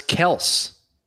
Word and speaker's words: Kelce: So I think it's Travis Kelce, like K-E-L-L-S. So Travis Kelce.